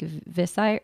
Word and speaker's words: Viseart: Um, but yeah, some of the other brands I use, I guess, are pretty much like Visi or Viseart is how it's it's spelled V I S E A R T, mm-hmm.